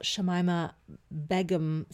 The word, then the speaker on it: Shamima Begum: I'm intrigued to hear your thoughts on the whole Shamima Begum situation. I don't even know how to say her name. Let's find out. – Shamima Begum.